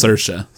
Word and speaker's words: Saoirse: Oh, it's Saoirse. Saoirse. It rhymes with inertia.